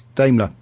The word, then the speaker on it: Daimler: Her car, wasn't it the Daumier? – I think that's close enough. Anybody going to object to that? – We don't have those here. How is that pronounced? – Daimler. That's fine.